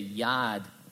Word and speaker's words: yard: His wife corrects his pronunciation of the word yard, saying, in Amity, you say yad.